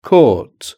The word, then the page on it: court: British English